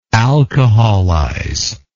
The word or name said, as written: alcoholize